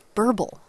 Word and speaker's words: burble: Burble. – Yes, it's the closest anybody's come to rhyming with purple.